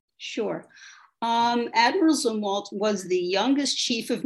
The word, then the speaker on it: Zumwalt: Am I saying that right? Zumwalt? – Zumwalt. – Zumwalt. – Yes.